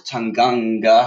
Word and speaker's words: Tanganga: Tottenham's defence consisted of Eric Dier, Alderweireld and Japhet Tanganga. How do you pronounce that? – Yeah, that's right.